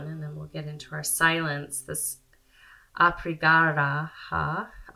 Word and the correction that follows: Aparigraha: Oh, I always say it wrong. Aprigraha.